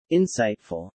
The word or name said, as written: insightful